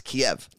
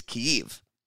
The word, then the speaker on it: Kyiv: The Russian pronunciation, which you've probably heard, is Kiev. – Yes. – The Ukrainian pronunciation, and although there's a subtle difference, it's substantial, is Kyiv. So you're basically replacing that last E with an I.